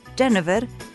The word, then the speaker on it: Genever: En francés se pronuncia Genève, en holandés Genever, y de ahí entonces Ginebra, aunque en realidad antes de decirle Ginebra le decían Gin, el diminutivo de Genever.